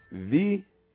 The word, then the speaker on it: Wie: And this is V, W-I-E, space, K-O-M-M-T, space, M-A-N, space, Z-U-M-N, with a question mark. V, and the W is always pronounced V as in Volkswagen, but you see it spelled like Volkswagen, but it's actually Volts with the V, Volkswagen.